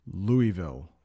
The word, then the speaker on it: Louisville: That's how you pronounce that city, Louisville, Louisville, Kentucky.